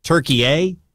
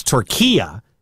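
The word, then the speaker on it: Türkiye: We've been pronouncing it wrong, and you're seeing people call it Turkey-A, but it's Turquia, he said, is the correct pronunciation.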